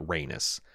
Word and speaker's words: Rhaenys: I'm sorry, I mispronounced that. Rhaenys.